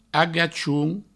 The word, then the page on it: agachon: agachoun